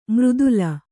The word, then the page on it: ಮರ್ದಲ: kannada